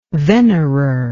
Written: venerer

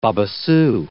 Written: babassu

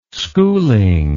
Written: schooling

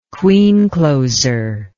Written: queen closer

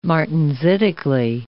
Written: martensitically

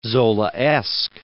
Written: Zolaesque

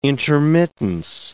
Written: intermittence

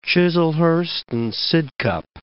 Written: Chislehurst and Sidcup

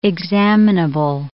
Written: examinable

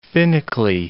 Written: finically

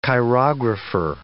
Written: chirographer

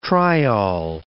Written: triol

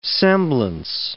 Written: semblance

